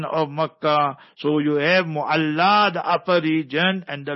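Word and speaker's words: Mu'alla (معلاة): it's called Mu'alla. Our people mispronounce it, or they pronounce it incorrectly, Mala Mala, not Mala Mala. It is no Mala Mala and no Pala Pala. It is Mu'alla.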